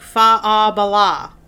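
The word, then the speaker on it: Faugh a Ballagh: It sounds like fa-a-bala. That's how it's said. Fa-a-bala. It means clear the way. And it's spelled really funky because it's Gaelic.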